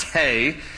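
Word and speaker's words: Hey (ה): Now, it's the Hebrew letter is just spelled H-E. So in English, we would look at that and say it's he, but it's pronounced Hey. in Hebrew, and so I'm going to read verse 33 to 40, and then we'll pray.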